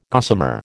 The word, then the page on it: gossamer: American English